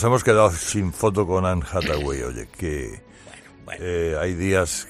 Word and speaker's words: Anne Hathaway: Y Anne Hathaway, que es como se pronuncia correctamente en inglés, como mucha gente sabe, tenía plancha la criatura.